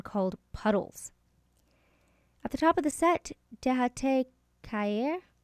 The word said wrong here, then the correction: Helado Tropical: I had it better pronounced in my head, and then I messed it up. But that's Helado Tropical.